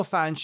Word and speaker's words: Phange: Anyway, I told them that, and a woman says, oh yeah, no, I know Fange. She says, and actually it's spelled P-H-A-N-G-E.